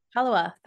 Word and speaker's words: Pellowah: Pellowah.